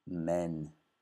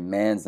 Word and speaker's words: men: Si dice men con una pronuncia con la E, non A. Quindi men. mens non si dice man's, non si dice questo è singolare, quindi men, perché sono, ce ne sono due, two.